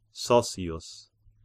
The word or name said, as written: Sossius